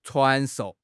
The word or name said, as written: 传授